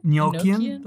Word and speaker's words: Enochian: I'm not sure if that's true, but it is used in some seal of Solomon Enochian magic. Hopefully I pronounced that somewhat close. – Enochian.